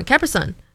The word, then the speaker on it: Capri Sun: So in Ashley's speech, I pronounce it the same way you would pronounce a Capricorn. So a Capri Sun, you know, like Capricorn, Capri Sun, instead of Capri Sun.